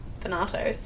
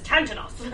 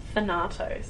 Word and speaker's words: Thanatos: Thanatos. – Is that how you say it? I always read it as Tantanos. I'm like, that's not right. – No. – What is it again? – Thanatos. – Thanatos, okay.